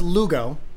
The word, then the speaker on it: Lugo: Well, it's pronounced Lugo, mm-hmm. and it's spelled L-U-G-O. – Mm-hmm. – And I've gotten all kind of like Lego, Luego, Lugastis, Lugenstein.